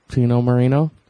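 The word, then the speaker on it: Chino Moreno: And his name rhymes with itself. – Does it? Yeah. Chino Marino? – Yep.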